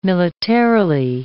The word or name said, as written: militarily